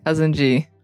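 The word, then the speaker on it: Kazandzhy: Yes, it's Cousin G. – And tell us how to spell it. – Not like you'd think. So it's K-A-Z-A-N-D-Z-H-Y.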